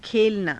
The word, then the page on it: khelanaa: Hindi